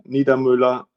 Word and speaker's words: Niedermueller: My name is Christian Niedermueller, as you pronounce this so greatly, Jamil.